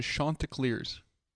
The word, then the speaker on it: Chanticleers: It is the Chanticleers.